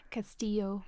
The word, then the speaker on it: Castillo: Castle in Spanish. – Do you say Castillo? – Castillo.